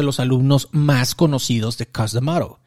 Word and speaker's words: Cus D'Amato: Esta frase se le atribuye a, lo siento, voy a tratar de pronunciarlo bien, Cus D'Amato. Lo estoy pronunciando bien, pero se escucha como que no lo estoy pronunciando bien. Cus, C-U-S, D, apóstrofe, Amato. Cus D'Amato.